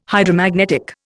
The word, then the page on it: Hydromagnetic: Indian